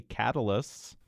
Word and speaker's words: Catullus: It's a usually Latin word, just as a quick thing, usually Latin words are stressed on the second to last syllable, the penult, unless for various reasons that the last syllable is short, then it goes to, like you pronounced it, Catullus. But in this case, the U is at the end is long, so it's Catullus.